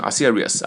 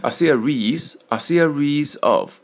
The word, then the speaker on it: series: 问他 a series of questions，这个 a series of 啊，注意这个不是要念成 a series 啊，a series of 长音啊，就是表示一系列，就上级问了下级一系列的问题，关于什么呢？About the supermarket，问了一系列关于超市的问题啊。